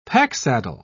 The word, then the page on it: packsaddle: British English